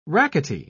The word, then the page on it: rackety: British English